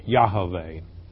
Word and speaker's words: Yahweh: It is YHVH, which is pronounced Yahweh.